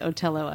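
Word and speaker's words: Othello: And there are many examples of TH being pronounced just as a T. – Neanderthal. – So here we go. – There you are. – So there, are, I think, is a solid possibility that Shakespeare may have pronounced it Otello as well.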